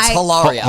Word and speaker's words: Hilaria: It's Hilaria.